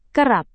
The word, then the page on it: kerap: indonesian